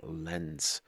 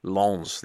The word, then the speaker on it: Lens: Lens. Lens? Why have I done that? I've gone all that effort to pronounce it as well as possible. Lens. There we go.